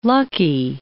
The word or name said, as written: lucky